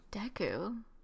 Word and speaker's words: Deku: Other bangers include pronouncing Deku as Dooku for Deku. – Like Zelda. – Oh. – A Deku okay. scrub. I said Dooku.